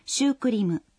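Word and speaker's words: сюкуриму (シュークリーム): В японском языке произносят «сюкуриму».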